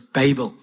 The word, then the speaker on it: Babel: It is pronounced that way, by the way. I checked. I wasn't sure how to say it, but it's Babel.